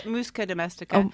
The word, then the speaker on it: Musca domestica: I said Musca Domestica.